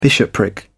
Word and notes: bishopric: British English

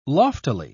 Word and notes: loftily: British English

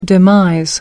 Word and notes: demise: American English

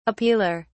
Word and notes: appealer: British English

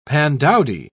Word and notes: pandowdy: British English

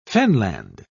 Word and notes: fenland: British English

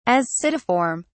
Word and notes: ascidiform: British English

